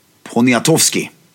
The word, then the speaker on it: Poniatowski: Alltså, om man vill ha polska stilgurus så finns det ju även till exempel en kille som heter Axel Poniatowski. – Det där satt. Gud vad du är duktig på uttalen på polska.